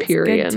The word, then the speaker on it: Empyrean: I don't even know if I'm saying that right. Empyrean. – It sounds Empyrean. Good to me.